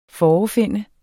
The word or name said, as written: FOREFIND